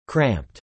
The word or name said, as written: cramped